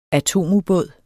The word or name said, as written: atomubåd